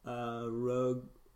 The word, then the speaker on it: Roggenrola: Well, Thatch, it happens to be Roggenrola. – Good pronunciation there.